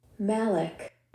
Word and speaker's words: malic: Malik.